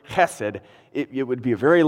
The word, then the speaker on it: chesed: The word that's translated as steadfast love is, and I'll pronounce it in Hebrew here, it's pronounced chesed. Chesed.